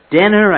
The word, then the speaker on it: Dehner: And a couple of years later, you played psychiatrist Elizabeth Denner, I guess it's pronounced D-E-H-N-E-R, the second pilot for Star Trek called Where No Man Has Gone Before.